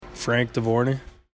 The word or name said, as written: Dovorany